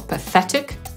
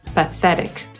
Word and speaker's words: pathetic: Podsumowując, angielski wyraz zapisywany pathetic czytamy po brytyjsku jako pathetic, po amerykańsku pathetic i oznacza żałosny, nie patetyczny. Żeby ułatwić sobie amerykańską wymowę, można powiedzieć pierwsze dwie sylaby pfe, a potem dodać polski wyraz ryk i też będzie brzmiało po amerykańsku.